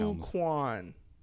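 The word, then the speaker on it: Ke Huy Quan: Ki-Hoo Kwan was the Best Supporting Actor. That was that was good pronunciation, I feel like.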